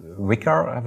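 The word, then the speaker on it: Wickr: Ein weiteres Amazon-Thema, über das ich gestolpert bin, ist Wicker, wird das, glaube ich, ausgesprochen.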